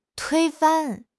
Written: 推翻

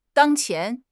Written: 当前

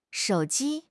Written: shou3 ji1

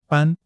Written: ban1

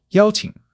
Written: yao1 qing3

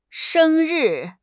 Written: sheng1 ri4

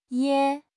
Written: ye4

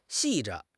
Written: 细致

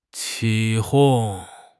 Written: 起哄

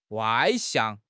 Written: 外向

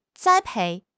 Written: 栽培

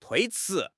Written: tui1 chi2

